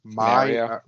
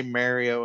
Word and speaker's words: Mario: I heard an Italian guy today say Mario. Mario, like Mario Lemieux, and it drove me nuts. It drove me absolutely nuts. Yeah, I was like, you would never say Mario and Luigi. Like, no. It's Mario.